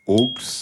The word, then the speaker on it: aulx: On dit que parfois, au pluriel, en effet, ce mot se dit aux et s'écrit A-U-L-X.